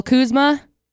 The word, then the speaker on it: Kuzma: Let's see, Lakers forward Kyle Kuzma. Did I say that right? – Yes.